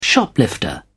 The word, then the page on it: shoplifter: British English